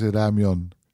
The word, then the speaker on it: ramyeon: Ja, het is oorspronkelijk Japans, ramen. De Koreaanse uitspraak is ramyeon.